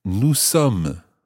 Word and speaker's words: sommes: Nous sommes. – Nous sommes. – And that's spelled S-O-M-M-E-S.